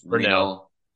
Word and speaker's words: Renault: It's pronounced so like it's the French spelling, so it's Renault, but I mean also it's a play on words, renal failure, like your kidneys are failing.